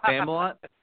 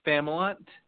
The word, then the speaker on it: Familant: So I'd like to bring Mike on and let's hey, Mike, how do you say your last name? I'm pretty sure I butchered it. – Yeah, you did. it's Familant. – What's that? – Familant.